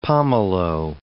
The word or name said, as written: pomelo